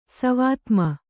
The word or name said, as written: ಸವಾತ್ಮ